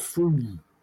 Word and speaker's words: Fruin: Mark Fruin. – Go on, moving on. – Or Fruin, but it's spelled F-R-U-I-N. Like, he came in through in the window.